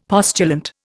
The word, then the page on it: postulant: Indian